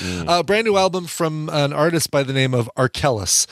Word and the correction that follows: Arkells: Arkellis is spelled A-R-K-E. I'm sorry, Arkells. A-R-K-E-L-L-S.